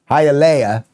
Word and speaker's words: Hialeah: Did I do it right? Did I say it right? That Leia? Hialeah. Okay.